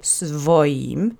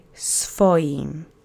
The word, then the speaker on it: swoim: Now, note that it says swoim, but in Polish, as sometimes happens, we try to simplify the syllables in our speech. So SW, sw would be pretty hard to pronounce, so we soften the W as sometimes happens, and we've seen this in other words before in prior lessons. So you don't say svoim, you say svoim. Accent is on the first syllable.